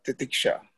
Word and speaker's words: titiksha: Mahesh, that dog tenacity, to me, it's equivalent to that word we have been using, titiksha, if I pronounce it correctly. – So, correct.